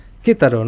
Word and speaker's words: Querétaro: Gracias, era la cronista, la periodista Estrella Álvarez desde Querétaro, como dijo que se dice exactamente. Tengo que aprender cómo se dice esa pronunciación. Querétaro, ¿no? De México, que está ahí a más o menos una hora y media, dos de Ciudad de México.